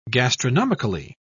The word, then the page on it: gastronomically: British English